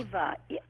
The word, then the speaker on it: Iwa: Where did the name Iwa come from for the dolphin? – The name what? – Was it Iwa or Iwa? – Eva. E-W, yeah, it's pronounced in Hawaiian. It's pronounced Eva.